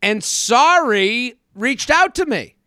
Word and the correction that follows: Sari: Yes. Sorry. Or Sari, which is her name, the way that her name is pronounced,